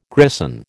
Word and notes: grison: American English

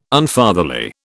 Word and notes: Unfatherly: British English

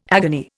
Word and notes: agony: Indian

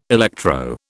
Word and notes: electro: British English

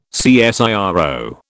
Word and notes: CSIRO: British English

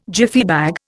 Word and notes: Jiffy bag: Indian

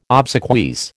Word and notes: obsequies: American English

